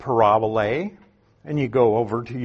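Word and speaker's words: parabole (παραβολή): When you look at the word parable in the Bible, if you had a Greek text sitting next to you, it would be the word parabole. They put the accent a little differently and put a long A at the end, but it's the same word, parable.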